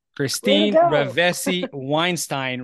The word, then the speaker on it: Christine Ravesi-Weinstein: to welcome Christine Ravesi-Weinstein. Did I say that right? – You did! – Christine Ravesi-Weinstein, right on.